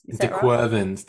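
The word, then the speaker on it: De Quervain: It hasn't been perfect with a baby and a toddler, up 2 feeds a night, postpartum surgery, prolapse, cortisone injections for I'm probably pronouncing this wrong, but dequar vein tendinitis. – Dequervans. Dequervans. Yeah.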